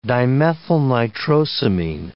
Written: dimethylnitrosamine

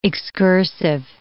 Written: excursive

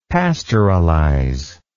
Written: pastoralize

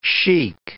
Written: chic

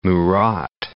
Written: Murat

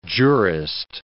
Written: jurist

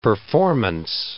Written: performance

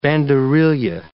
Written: banderilla

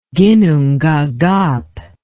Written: ginnungagap

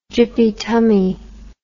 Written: gyppy tummy

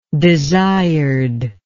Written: desired

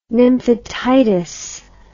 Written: nymphetitis

